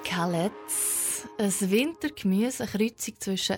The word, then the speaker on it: Kalettes: Nein, das kommt eigentlich von Kale und wir sagen Kalettes. – Kalettes.